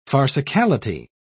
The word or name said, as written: farcicality